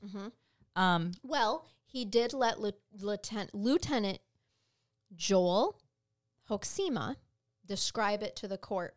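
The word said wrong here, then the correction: Hoksema: You want to know how to pronounce a good Dutch name? – But how? – Hoxma. – Yeah, that actually makes more sense than what I said.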